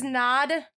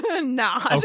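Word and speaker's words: Knod: This is Nod. It's spelt like Todd, but with KN. – Nod.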